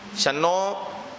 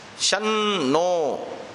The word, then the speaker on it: शन्नो: शन्नो गलत उच्चारण है, शन्नो ये सही उच्चारण है।